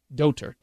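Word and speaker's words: dóttir: Or D-O-T-I-R. Doter. – D-O-T-I-R. – That's how you pronounce and spell it. Doter. D-O-T-I-R.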